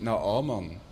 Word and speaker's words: Naaman: Und deshalb sind die neuen Übersetzungen, übersetzen dann mit Naaman.